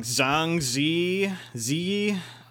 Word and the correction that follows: Zhang Ziyi: I, I most often see it as Zhang Zi Yi. Zhang Ziyi.